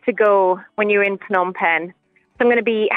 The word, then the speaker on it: Phnom Penh: We'll catch up with you again tomorrow with more from Phnom Penh.